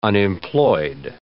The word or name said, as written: unemployed